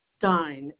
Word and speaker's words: Stein: Stein is the last name. S-T-E-I-N.